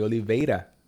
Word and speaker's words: Oliveira: Um, Chocolate Yoddah, I think you're the first person that pronounces my name correctly, Oliveira.